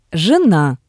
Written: jena

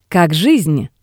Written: Kak gisn

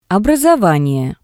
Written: obrasovanie

